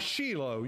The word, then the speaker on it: Shiloh: At this time, the Ark was in a city called, we pronounce it Shiloh. In Israel, they pronounce it Shiloh. You know, when you put the emphasis on a different syllable, it makes the word sound funny.